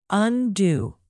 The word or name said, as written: undue